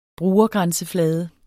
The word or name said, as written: brugergrænseflade